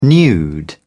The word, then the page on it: nude: British English